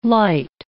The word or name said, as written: lite